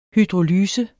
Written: hydrolyse

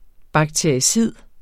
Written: BAKTERICID

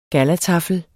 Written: gallataffel

